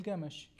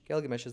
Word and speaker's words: Gilgamesh: Can you open the Gilgamesh? Is that the correct pronunciation? – Gilgamesh. – Gilgamesh is the correct pronunciation.